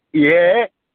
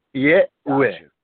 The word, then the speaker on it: Yahweh: So it would be Yahweh. Because we pronounce the last E, which causes the U to sound like a F sound, which births the W.